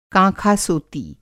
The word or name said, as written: काँखासोती